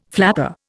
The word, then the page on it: flapper: Indian